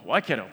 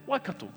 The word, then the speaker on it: Waikato: When I first moved to Hamilton, I just used Waikato, Waikato, Waikato Chiefs, Waikato rugby team, and that's because everyone else said it, I say the same. But when I started to learn a thing about language and things like that, once you learn something, it's hard to unlearn it. And I realized, actually, the correct way to say it is Waikato.